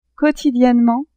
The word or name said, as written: quantitativement